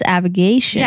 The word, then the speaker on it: Avigation: Yeah, I thought I was trying to spell aviation, and I was like, that is not how it is pronounced. It's Avigation.